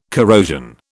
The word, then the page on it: corrosion: British English